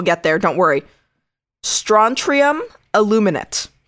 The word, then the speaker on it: aluminate: but aluminate as in like aluminum, not like illuminate.